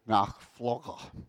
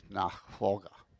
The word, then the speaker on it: Nachfolge: Diedrich Bonhoeffer, in his book Nachfolger, best way I can pronounce it, N-A-C-H-F-O-L-G-E, Nachfolger,